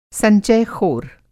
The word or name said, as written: संचयख़ोर